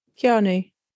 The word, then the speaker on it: Keanu: Keanu.